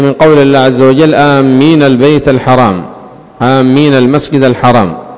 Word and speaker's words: آمين: وفي آمين لغات، قال العلماء أفصحها آمين بالمد وتخفيف الميم آمين، والثانية بالقصر، وهاتان مشهورتان. وكيف تكون بالقصر؟ كيف تنطقها بالقصر أمين يعني بدون مد؟